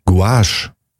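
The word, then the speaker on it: gouache: G-U-A-C-H-E. – G-O-U-A-C-H. – Guache. – Guache. – Guache. – So spricht man es aus.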